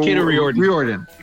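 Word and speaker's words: Cait O'Riordan: Oh, how do you pronounce her name? Oh, Kate O'Riordan.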